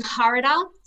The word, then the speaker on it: Harada: When he went over to Tokyo and versus Japanese champion Masahiko fighting Harada. Sorry. That's probably the wrong pronunciation. – No, it was good.